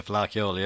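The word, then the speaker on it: Fleadh Cheoil: And Belfast just had the flare. Have I pronounced that correctly? – The Flá Cheoil, yeah.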